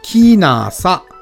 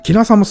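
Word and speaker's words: 鬼無里: そしてちなみにここの近くにですね、鬼のない里と書いて、キーナーサという村もございます。うん、キナサもすごい、うん、いい場所ですよ。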